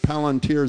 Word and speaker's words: Palantir: He buys roughly $100,000 of Palantir.